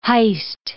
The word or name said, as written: heist